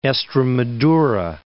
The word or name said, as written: Estremadura